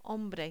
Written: hombre

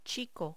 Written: chico

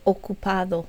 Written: ocupado

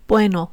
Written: bueno

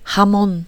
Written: jamón

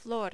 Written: flor

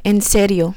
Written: en serio

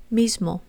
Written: mismo

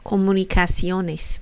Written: comunicaciones

